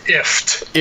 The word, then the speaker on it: IFTTT: And you also had mentioned the IFTTT. – It's pronounced IFT. – IFT. – Yes. Rhymes with gift. – Gift. – Or lift. Yes.